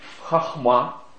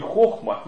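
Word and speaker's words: хахма (חכמה): В библейском смысле понятие мудрость, она произносится на еврейском языке как хахма, не хохма, есть хохма, да, шутка, да, а как хахма, ударение на последний слог.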